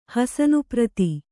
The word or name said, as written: ಹಸನುಪ್ರತಿ